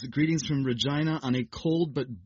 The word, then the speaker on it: Regina: Anyways, this person is writing with greetings from Regina, Saskatchewan in Canada. – Which I had mispronounced. – You said Regina, I think, and I was so embarrassed because I knew the correct pronunciation, and I shut my yapper.